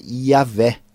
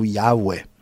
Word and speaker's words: Yahweh: A pronúncia desse nome, segundo os melhores estudiosos da linguística, da filologia antiga, provavelmente era Yahvé ou Yahweh.